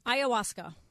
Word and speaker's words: ayahuasca: Ayahuasca.